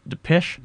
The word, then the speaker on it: Depeche Mode: Just earlier there, we heard Depeche Mode. Is that how you pronounce it? Depeche? – Yes.